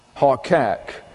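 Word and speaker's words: haqaq: and that is spelled H-U-Q-Q-A, pronounced hukal. And it comes from a root word, hakak, that's H-A-Q-A-Q, hakak, and that means to engrave.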